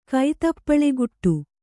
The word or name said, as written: ಕೈತಪ್ಪಳೆಗುಟ್ಟು